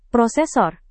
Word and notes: prosesor: Indonesian

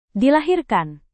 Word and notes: dilahirkan: Indonesian

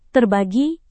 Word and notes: terbagi: indonesian

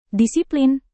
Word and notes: disiplin: indonesian

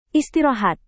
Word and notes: istirahat: indonesian

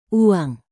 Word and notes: uang: indonesian

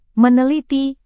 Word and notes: meneliti: Indonesian

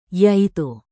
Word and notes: yaitu: indonesian

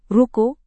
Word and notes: ruko: indonesian

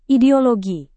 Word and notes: ideologi: Indonesian

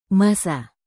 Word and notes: masa: Indonesian

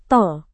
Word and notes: tol: Indonesian

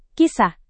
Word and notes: kisah: Indonesian

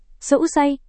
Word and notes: seusai: indonesian

